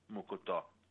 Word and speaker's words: mocotó: I mean, they even make, in Brazil, they even make mocotó. It's called M-O-C-O-T-O with the accent on the third syllable, mocotó.